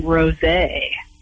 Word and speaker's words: Rosé: Not All Diamonds and Roses by David Quinn. – You said that wrong. It's Not All Diamonds and Rosé.